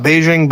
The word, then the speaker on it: Beijing: Are you talking about Beijing Biden? – Beijing. – Beijing? – Yeah. It's a hard J.